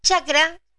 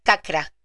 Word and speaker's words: chakra: Chakra eh, en sánscrito, sánscrito se escribe o se, dice cacra, no chakra con ch.